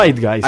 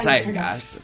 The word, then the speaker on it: Zeitgeist: No me acuerdo cómo se pronuncia. – Zeitgeist. – Ahí está, Zeitgeist.